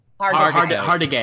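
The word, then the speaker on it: Hargitay: How do you say her name? – Hartigay.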